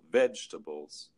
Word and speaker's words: vegetables: American English pronunciation is stressed the first syllable, vegetables.